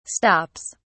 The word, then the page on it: stops: British English